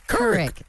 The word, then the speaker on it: Couric: Couric.